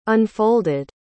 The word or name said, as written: unfolded